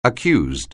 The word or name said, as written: accused